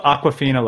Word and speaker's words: Awkwafina: But it's also, it's spelled Awkwafina like awkward, though, not Aqua like Awkwafina.